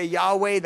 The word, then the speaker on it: Yahweh: And so he's saying, our Lord, YHWH, the unpronounceable, and we say Yahweh, that's as close as we're going to get.